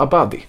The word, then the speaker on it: Abadi: Adabiha. Adaba. Ad... Men Gud Johan. – Ja men du kan ju inte uttala det här. Nu, Adabi. Adabi. Farah Adabi. – Farah, Farah Abadi.